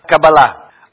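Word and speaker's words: Каббала: Первое ударение куда? Кабала или Кабала? – Кабала.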